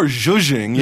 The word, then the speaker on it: zhuzhing: What is, if I'm pronouncing it correctly, zhuzhing? – Kind of more zhuzhing. Yes, zhuzhing? – Exactly.